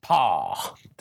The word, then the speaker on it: Pa: And this is Pa Kilter from the DGC forums, and he says, "Tell dude it's pronounced." pronounced paw. Did I pronounce it right? Paw. Uh, paw, because I'm saying like PA.